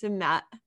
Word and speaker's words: sumac: but we also use sumac, which is pronounced sumat in Arabic, but it's basically a berry that is very acidic.